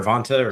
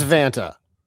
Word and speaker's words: Vanta: Is it Vanta or Vonta? Or? – It's Vanta.